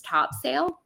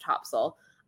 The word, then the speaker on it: Topsail: I realized for many years I was mispronouncing Topsail. I thought it was pronounced Topsail because that's how it's like phonetically spelt. And one day we realized after we'd been staying there for years that we'd been pronouncing it wrong all along. So I now have been corrected by true North Carolinians and I know that it is pronounced Topsail.